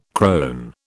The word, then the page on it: crone: British English